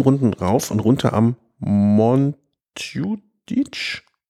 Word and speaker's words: Montjuïc: Vereinfacht würde er sagen Montjuïc. Schön ausgesprochen.